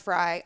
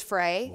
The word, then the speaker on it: Frey: So it's pronounced Fry, obviously. But everyone says Frey.